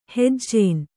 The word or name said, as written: ಹೆಜ್ಜೇನ್